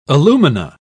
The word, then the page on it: alumina: British English